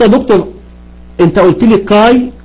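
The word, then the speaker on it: كاي (καί): قال لك إن الألفا يوتا تنطق آي آي ألف ي آي، يبقى كاي تنطق آي ألف ي، ودي متصورة وهتشوفوها. يبقى قال لك إن الألفا يوتا تنطق آي، يبقى ما أقول كبا ألفا يوتا تنطق كاي.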